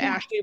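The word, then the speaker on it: Ashlee: Like I'm Ashley with L-E-E, not L-E-Y.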